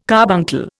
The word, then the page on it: carbuncle: Indian